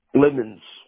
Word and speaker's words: Lemons: Lemons. – Can you spell that? – L-E-M-O-N-S. – Oh, Lemons. L-E-M-O-N-S.